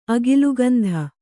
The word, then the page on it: ಅಗಿಲುಗಂಧ: Kannada